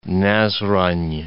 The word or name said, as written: Nazran'